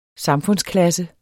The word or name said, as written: samfundsklasse